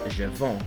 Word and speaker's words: je vends: And of course, the S is silent, so it's pronounced je vends, meaning I sell or I am selling. – And remember to write the S, but not to pronounce it.